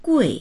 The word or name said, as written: gui4